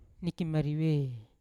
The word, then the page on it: nikimarive: Yawa